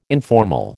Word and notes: informal: American English

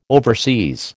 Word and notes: overseas: American English

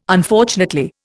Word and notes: Unfortunately: Indian

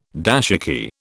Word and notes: dashiki: British English

